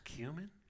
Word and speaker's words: cumin: It's pronounced cumin. Oh my gosh, I've been pronouncing it wrong.